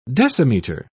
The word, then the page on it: decimeter: British English